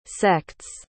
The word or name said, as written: sect's